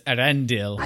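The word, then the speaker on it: Eärendil: So, lights up the Star of Elendil. I'm sure I said that wrong. – It's Erendil.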